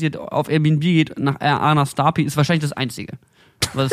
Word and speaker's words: Arnarstapi: Arnarstapi. a r n a r s t a Da sollen die Leute jetzt hinfahren. – Arnarstapi, da war ich.